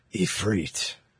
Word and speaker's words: Ifrit: Excuse me, you have to say it like Ben does. Ifrit.